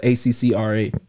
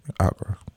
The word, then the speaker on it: Accra: I know it's ACCRA. – Accra.